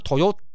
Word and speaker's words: Toyoda: Toyota 这个车子呢，各位如果你对它稍微有研究就知道，其实 Toyota 最早它的名称呢，并不是 Toyota，它是 Toyoda 啊，T O Y O D A。那因为为什么是 D A 呢？因为很简单。因为在日本的这个这个发音方式呢，丰田啊、哦，丰田它你直接这样翻的话是 T O Y O D A 啦哦，只是呢这个丰田他们觉得说，哎，如果用 T O Y O D A 的话呢，